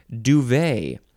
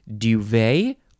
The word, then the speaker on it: duvet: Now, it looks like you say duvet or duvet. And you have to get the little Y sound in there, and you can emphasize either syllable. It is spelled D-U-V-E-T. Don't forget about the T.